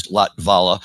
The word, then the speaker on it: Latvala: And thank you for correcting me on the pronunciation of his last name, which is Latvala. It just was always difficult to say because you got that T and V right next to each other without a vowel.